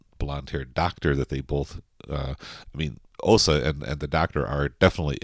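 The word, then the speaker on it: Åsa: I mean, it's spelled A-S-A, but it's got that little circle at the top of the A, so I guess it's pronounced Osa.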